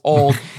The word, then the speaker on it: Auld: And for clarification, Auld Lang Syne, the song, it's the first word is A-U-L-D, which is hilarious because that's also American Ultimate Disc League, but it's pronounced old. And the first choice is old as in O-L-D Lang Syne.